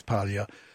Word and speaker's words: Paglia: And I, I we talked about Camille Paglia, Paglia actually pronounced correctly is Paglia um,